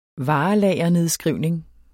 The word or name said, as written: varelagernedskrivning